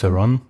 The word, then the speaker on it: Theron: Theron, Wie spricht man das richtig aus? – Ja, passt. Theron.